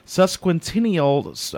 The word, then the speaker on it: Sesquicentennial: He will be talking about the Independence Sesquicentennial. I think I said that right. – You did. – that word's always been hard, but he's going to be talking about the Independence Sesquicentennial.